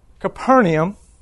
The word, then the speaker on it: Capernaum: See, so Jesus left his house in Capernaum, am I saying that right? – Capernaum.